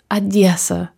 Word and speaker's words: Odessa: Also eigentlich, was ich kenne, ist, wie die Leute, die nicht mehr in Odessa sind, wie die es sagen. Und das ist Odessa.